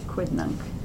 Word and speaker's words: quidnunc: It's not a soft C. Quidnunc.